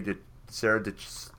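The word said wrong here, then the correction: Dietschy: DiCci, it rhymes with peachy.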